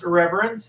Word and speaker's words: IRRVRNT: So it's pronounced reverence.